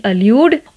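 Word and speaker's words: allude: first word की spelling है A double L U D E अल्यूड और दूसरे वर्ड की स्पेलिंग है E L U D E एल्यूड अल्यूड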